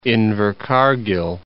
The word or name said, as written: Invercargill